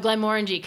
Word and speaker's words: Glenmorangie: So Glenmorangie, confirm how it's pronounced. – It's the only word that rhymes with orangey.